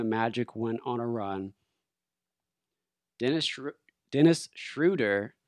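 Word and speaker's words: Dennis Schröder: I know I just said his name incorrectly, but this man deserves a proper pronunciation of his name. Tonight, we will call him Dennis Schroeder.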